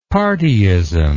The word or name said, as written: partyism